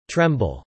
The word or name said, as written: tremble